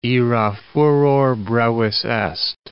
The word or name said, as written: ira furor brevis est